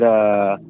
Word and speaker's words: aswang: We pronounce it aswang. – Aswang? – That's how we pronounce it there.